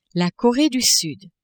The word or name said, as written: Coree du Sud